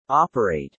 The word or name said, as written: operate